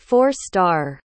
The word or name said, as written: four-star